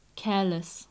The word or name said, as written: careless